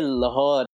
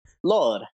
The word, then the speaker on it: Lahore: Like, when people say Lahore, it's not how it's said, it's Lore. – Lore, yeah. There's a weird tone in there. It's not even just Lore, it's like Lore. – Yeah. – Like a silent H.